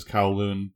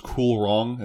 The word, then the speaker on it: Kowloon: I think the official pronunciation is Kowloon, but like it's romanized as Kulong in Cybersleuth for some reason, and I'll never forget that.